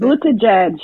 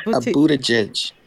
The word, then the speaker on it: Buttigieg: Transportation Secretary Pete, and I cannot pronounce his last name. I know Senate has told me several times. Buttigieg. What's his last name? How you pronounce it? – Buttigieg. – Buttigieg. – Buttigieg. Thank you, ladies. Appreciate you.